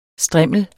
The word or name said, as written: STRIMLERNES